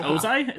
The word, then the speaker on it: Ozai: Was it Ozai? Is that how you say it? – Yeah.